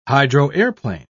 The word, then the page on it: hydroaeroplane: British English